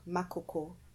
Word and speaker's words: Makoko: They're doing great work in, I know I'm gonna say this wrong, Makoko. But I think I tried. – You actually said it right.